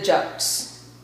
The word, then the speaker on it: Jut: It's a little bit like, and I don't want to say royalty, but you know, it's called the Juts. J, you could say J-U-T, Jut, that's how you would pronounce it.